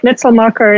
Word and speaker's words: Mitselmakher: Yep, Mitzelmaker, and it's Block Accelerate.